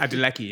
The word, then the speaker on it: Adeleke: Is, is it Rhasidat Adeleke? – Adeleke, yeah. Adeleke, yeah.